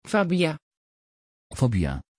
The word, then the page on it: fabia: Dutch